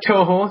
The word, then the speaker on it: Toho: Am I pronouncing that correctly, or is it... – Toho, that's right, yeah.